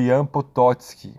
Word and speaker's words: Potocki: Vocês vão ver grafado aí, né, o nome dele como Jan Potocki com CK, né? Mas a pronúncia, né, como ele era da Polônia, né, ele era polaco, né, acaba sendo Jan Potocki, OK?